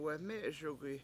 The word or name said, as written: weME7eSugi